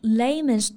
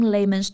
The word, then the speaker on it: layman: 所以呢，如果你 explain something in layman's terms，就是说用非常简单的、通俗易懂的语言来解释。发音上啊，我们注意一下 layman 它的元音 lay，中间是一个双元音 a lay。后面的 man，注意呢，在合成词里面我们就不发梅花音 man，而是弱化成一个 schwa 的央元音，所以不是 layman，而是 layman， layman。